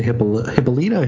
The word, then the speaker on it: Hippolyta: And this changed Wonder Woman's origin so that instead of being made out of clay, she was born to Hippolyta? Hippolyta? How do we say that? – Either one's better. Okay. Either one's okay.